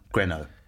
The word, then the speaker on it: Grineau: It's Greno. So if you were to write it phonetically, it'd be G-R-E-N-O, Greno, or Greno, if you're going to pronounce it French. My name's also French. – They were both so French. – It's impossible to anglicise, because it's spelt G-R-I-N-E-A-U, which is, if I was to do a Domet, it would be Grinu.